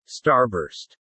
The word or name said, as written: Starburst